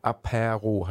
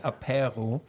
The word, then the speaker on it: Apéro: Ja, Apéro heißen die. – Ah, okay, Apéro. – Also das heißt, dass dieser Accent d'aigu heißt, das wird länger ausgesprochen, Apéro. – Okay. – Wie in Aperol.